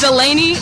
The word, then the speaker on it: Delaney: My name is Delaney, D-E-L-A-N-E-Y. – What's your last name? – My name is Coates, C-O-A-T-E-S.